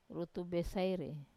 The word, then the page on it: rutu besaire: Wamesa